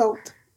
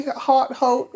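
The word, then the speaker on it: haute: But most people say hot, hot. It's French, and the French don't pronounce the H if it's in front of a vowel, so it's silent. It's just oat.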